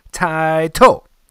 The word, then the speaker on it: Taito: You'll hear the manufacturer says Taito, so Taito is probably the correct pronunciation of that, and Taito is you know, but I still call it Taito.